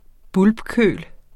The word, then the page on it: bulbkøl: ˈbulb-; ˈbʌlb-